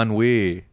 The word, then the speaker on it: ennui: Uni.